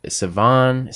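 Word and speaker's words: Siobhan: It's like spelled Savan. Savan. It's Greek. But it's spelled so strange. It's like S-I-O-B-A-H-N.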